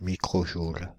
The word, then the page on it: microjoule: France (Île-de-France); /mi.kʁo.ʒul/